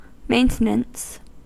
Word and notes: maintenance: American English

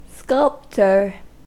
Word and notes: sculptor: American English

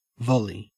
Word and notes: volley: Australian English

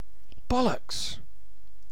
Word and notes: bollocks: British English

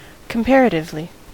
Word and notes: comparatively: American English

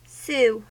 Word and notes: sue: American English

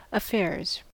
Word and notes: affairs: American English

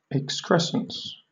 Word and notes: excrescence: British English

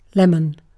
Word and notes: lemon: British English

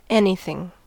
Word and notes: anything: American English

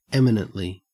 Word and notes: eminently: Australian English

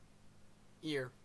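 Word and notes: ear: Canadian

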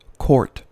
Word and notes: quart: American English

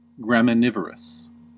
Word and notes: graminivorous: American English

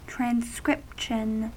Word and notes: transcription: American English

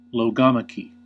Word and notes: logomachy: American English

